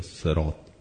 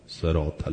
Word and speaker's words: صراط: توی قرآن میدونید کلماتی که صاد و تا یا صاد و قاف جمع شده باشه، صاد رو سین هم میشه تلفظ کرد. اهدنا الصراط المستقیم یا صراط صراط المستقیم، هر دوش درسته.